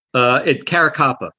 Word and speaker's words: Caracappa: Caracappa.